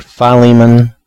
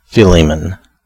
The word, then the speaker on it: Philemon: So, the accepted pronunciations appear to be either Philemon or Philemon.